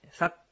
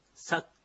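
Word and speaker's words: サㇰ: サッというものなんですが、このカタカナで書きますと、サの後にちっちゃいクがついています。これがちょっと、まあ、アイヌ語にない、アイヌ語には、の独特の発音でして、日本語にはない発音です。ちっちゃい句なんですね。もう息を止めるような発音です。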